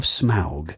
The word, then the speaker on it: Smaug: And if you're listening without the ads on Radio Premium, something from The Hobbit, The Desolation of Smaug.